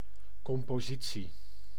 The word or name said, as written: composition